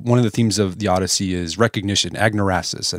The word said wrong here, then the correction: anagnorisis: I think that agnorasis is how you pronounce it. – Anagnorisis. – Anagnorisis. Yeah, I'm saying it how we say it out here in Oklahoma.